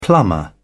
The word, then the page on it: plumber: British English